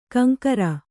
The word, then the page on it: ಕಂಕರ: kaŋkara